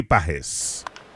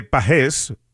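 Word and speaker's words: Pagés: Y si escuchaste a José Mota y escuchas que dice de Pájez, es que así es, así es que realmente se pronuncia Andy Pájez, pero obviamente Andy pues ha dejado que le llamen Pájez porque ya es de costumbre, pero correctamente es como lo está anunciando y pronunciando José Mota.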